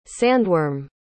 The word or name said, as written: sandworm